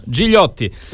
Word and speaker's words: Gigliotti: E noi lo chiediamo allora a Guilherme Gigliotti, o Gigliotti, adesso ci dirà lui se è meglio la pronuncia in francese o in italiano.